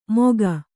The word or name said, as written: ಮೋಘ